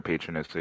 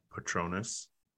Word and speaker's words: Patronus: People know who their what their patroness is. I don't think you know all that. – Patronus.